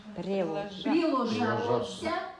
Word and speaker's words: приложатся: И сия вся приложится. – Нет, не приложится. – Приложаться. – Приложаться, да, вот ударение. – Только не ца, а приложаться.